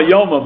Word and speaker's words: Yoma: I don't know if you pronounce it Yuma or Yoma, by the way, that's up to you. Anyway, we'll call it Yuma the way they do in Lakewood.